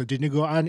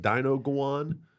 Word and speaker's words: dinuguan: So dinuguan is a pork blood stew. – Wait, say that word again. – Dinuguan. – Dinuguan. – Dinuguan? – Dinuguan. – Yeah. – Is it pronounced like dinoguan? – Almost.